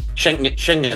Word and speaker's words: Schengen: Schengen.